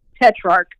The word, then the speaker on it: Tetrarch: Tetrarch.